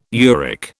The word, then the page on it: uric: British English